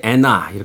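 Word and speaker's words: Anna: 토토의 음악 중에서 안나 듣습니다. 토토의 안나 듣고 왔습니다. 원래는 이제 애나 이렇게 발음하는데 예, 오늘은 주인공이 안나니까 그렇죠, 그렇죠. 예, 안나로 읽어드렸습니다.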